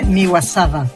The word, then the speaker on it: Miyazawa: La pronunciación é Miyazawa.